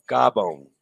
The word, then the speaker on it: Gabon: Gabon. Did I say it wrong? Gabon. I know that one.